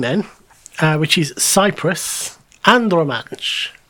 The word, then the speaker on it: Andromache: And I was like, surely it's Andromache, because Andromache is a character in Greek mythology. – Yeah, that's where I know the name from. That's why I pronounce it the way it does.